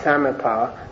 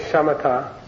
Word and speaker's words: shamatha: Some people leave out the H here because there used to be a diacritic there and they left it out when someone printed it and they call it samatha. Samatha. This is the correct pronunciation. It's going to say shamatha.